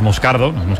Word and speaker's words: Moscardo: Como bien ha pronunciado Unai, es Moscardo, brasileño, sí.